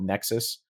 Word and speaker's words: Nexuiz: Nexus. It's spelled weird. It's like N-E-X-U-I-Z.